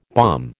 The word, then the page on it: bomb: American English